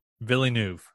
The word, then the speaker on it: Villeneuve: There's a V on the end, dog. – Yeah, but it's French, dog. I didn't know that. – So Villeneuve. There's words. There's letters that they do pronounce, and words that it. Fuck. – There's letters that they do pronounce, and other ones that they don't. – Yes, and V is one of the ones that do get pronounced at all. – V is always pronounced.